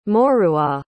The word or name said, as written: Maurois